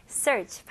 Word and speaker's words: search: Okay, 여기서는 R 발음 힘을 주시고 마지막 CH 발음으로 마무리해 주셔야 됩니다. 그리고 search 아니에요. 네, 이거는 외래어고 영어식 발음은 search. search. 여기 EA가 U라고 상상해서 한번 읽어보세요.